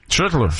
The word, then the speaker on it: Schlittler: Schlittler. J'aime ça de la manière que tu l'as très bien prononcé.